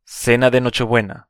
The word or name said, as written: cena de Nochebuena